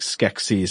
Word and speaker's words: Skeksis: Now, you're not pronouncing sexy in a funny way. You're saying like Skeksis, yes. Like in the Dark Crystal.